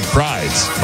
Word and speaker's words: Prydz: Is it Eric Prydz? – Yeah. It's Prydz. P-R-Y-D-S. I've heard it said both.